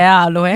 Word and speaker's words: aloe: Sergio chiede, aloe sulla O o sulla A l'accento? Io dico aloe, non aloe. – Io dico aloe, però dice forse aloe. È aloe.